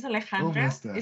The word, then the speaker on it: Alejandra: Almost there. It's Alejandra. It's a Spanish, but you can call me Ali, like everyone else.